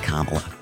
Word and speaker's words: Kamala: Except, like, it's pronounced Kamala.